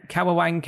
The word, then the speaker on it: Kawawang: Kawawang Cowboy.